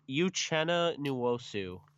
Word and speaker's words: Uchenna Nwosu: I think Uchenna, and I might get this name wrong, Uchenna Nwosu. It's Uchenna Nwosu.